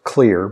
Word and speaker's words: Xlear: Your son, Nate Jones, has been on the program talking about clear nasal spray, spelled X-L-E-A-R. The X stands for xylitol, so it's pronounced clear, but it's spelled X-L-E-A-R.